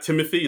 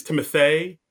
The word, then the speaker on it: Timothée: Yeah, so it's the same thing where it's not Timothy, it's Timothée.